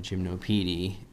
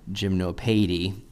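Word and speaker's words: Gymnopédie: He said, hey man, you know, I like the Seed AI interpretation of Gymnopedie. And actually, it's probably pronounced Gymnopedie,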